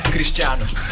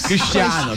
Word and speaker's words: Cristiano: ¿Cómo se dice Cristiano Ronaldo, Tiano? – Cristiano. – Cristiano. Cristiano.